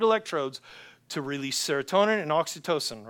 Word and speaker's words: oxytocin: I can't pronounce it right. Oxytocin. Is there a nurse that can help me here? oxytocin? Oxytocin, thank you.